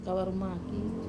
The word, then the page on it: kavaru maki: wamesa